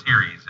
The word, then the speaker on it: Ceres: It's spelled Ceres. – Mm-hmm. – It's pronounced Ceres, if anybody wants to look up.